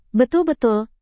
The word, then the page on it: betul-betul: Indonesian